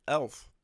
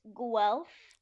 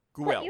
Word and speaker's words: Guelph: Is it Guelph or is it Elf? – It's kind of like you're saying a W, like it's Guelph. – Guelph.